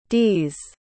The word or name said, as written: d's